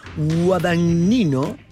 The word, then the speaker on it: Guadagnino: La película, dirigida por el aclamado cineasta Luca Guadagnino,